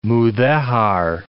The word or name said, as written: Mudejar